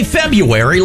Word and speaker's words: February: Just say February like everybody else does. – That's not how it's spelled. – That doesn't matter. Sometimes letters are silent. And I'm making that one silent. Hush up, R.